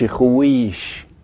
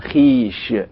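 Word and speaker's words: خویش: عندنا كلمة خيش، ما بنقولش خويش إنما بنقول خيش،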